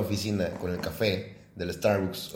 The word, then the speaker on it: Starbucks: como, no se dice Starbucks, se dice Starbucks.